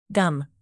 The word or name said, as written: gum